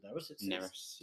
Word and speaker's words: Nereus: So, asteroid Nereus, I think it's called Nereus.